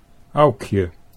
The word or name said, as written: Aukje